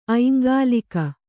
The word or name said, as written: ಐಂಗಾಲಿಕ